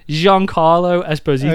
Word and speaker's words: Giancarlo Esposito: Giancarlo Esposito.